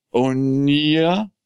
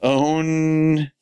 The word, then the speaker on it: Ouya: Onya. – I don't know. I was hoping you would pronounce it. – Ouya.